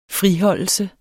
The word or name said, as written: friholdelse